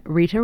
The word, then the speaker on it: Rita: My name, Rita Rochelle, is spelled R-I-T-A. Rochelle is spelled R-O-C-H-E-L-L-E.